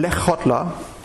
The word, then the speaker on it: Lechotla: Wird ausgesprochen Lechotla.